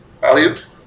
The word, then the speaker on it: Aleut: Anyway, 1815, Peter the Elute was martyred, killed. According to Orthodox history, Aleut? Thank you. Well, because it's the Aleutians, I'd never heard. Thank you very much. I'd never heard it pronounced.